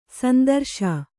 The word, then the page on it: ಶಾಂತರಸ: Kannada